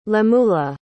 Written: lemuela

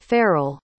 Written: farrel

